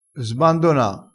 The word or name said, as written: sbandona